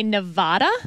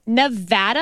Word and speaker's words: Nevada: Because a lot of politicians would say Nevada. – Wrong. – And that is wrong. – Wrong. It is Nevada. And I had to train myself to say Nevapple so I could pronounce the A right.